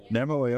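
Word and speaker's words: Namoi: did I pronounce that correctly? Nemoi, okay, Cotton Limited, and Technical Director of Fiber Trace.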